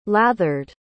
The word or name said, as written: lathered